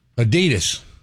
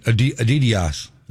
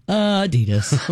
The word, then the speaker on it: Adidas: Adidas. – Yeah. – How do you say it? – Well, it's supposed to be a slight emphasis on the first syllable, but we put it on the D.